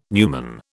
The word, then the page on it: numen: British English